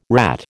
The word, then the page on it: rat: American English